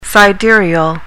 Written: sidereal

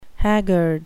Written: haggard

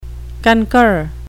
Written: concur

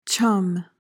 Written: chum

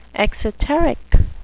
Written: exoteric

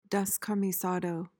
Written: descamisado

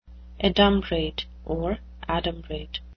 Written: adumbrate

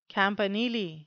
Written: campanile

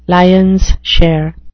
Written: lion's share

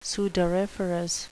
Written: sudoriferous

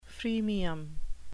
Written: freemium

